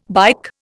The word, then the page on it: bike: Indian